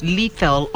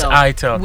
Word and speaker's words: itel: I used to pronounce it as lethal until she told you it's ITEL.